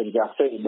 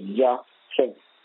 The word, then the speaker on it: Jahseh: You all pronounce it in America, Jahseh, but his name is Jahseh.